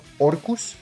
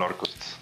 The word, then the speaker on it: Orcus: La primera viene siendo Orcus, es un príncipe demonio de los no muertos ¿Cómo se escribe eso? – O-R-C-U-S.